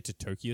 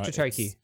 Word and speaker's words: Totoki: Hiroki Totoki, who's the CFO, is quoted saying... – I'd say Totoki, that sounds right. Yeah, Totoki.